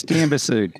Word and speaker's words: Diamba Sud: How are you, here's your chance to correct Maddie's mispronunciation of the project. Did you get it right or wrong? Diam Bassoud. – Oh, you got pretty close. Diam Bassoud. – Yeah. Diam Bassoud. – Bassoud. Depends how you pronounce it.